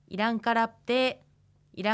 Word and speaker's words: イランカラプテ: プはプーと言わずにただ口を閉じるようにして発音しますイランカラプテーイランカラプテーのように発音していきます